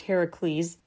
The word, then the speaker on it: Heracles: It's a part of Greek mythology, long story short, Hercules, yeah, Heracles, if you want to be one of those well-actually people. It's pronounced Heracles.